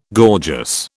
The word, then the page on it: gorgeous: British English